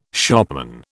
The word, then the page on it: shopman: British English